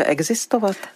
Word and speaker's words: existovat: Tak nevím, jestli jsem to řekla dobře, existovat. – Řekla jste to dobře.